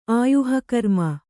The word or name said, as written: ಆಯುಃಕರ್ಮ